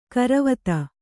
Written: ಗೊರವಿತಿ

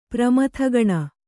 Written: ಪ್ರಮಥಗಣ

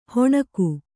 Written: ಹೊಣಕು

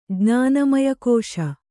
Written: ಜ್ಞಾನಮಯಕೋಶ

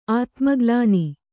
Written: ಆತ್ಮಗ್ಲಾನಿ

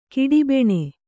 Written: ಕಿಡಿಬೆಣೆ

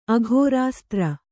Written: ಅಘೋರಾಸ್ತ್ರ

